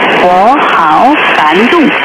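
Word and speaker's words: 워하오간동 (我好感动): 워하오간동. 워하오간동입니다. 감동받다 할 때 그 감동과 발음이 비슷하죠.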